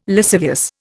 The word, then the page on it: lascivious: Indian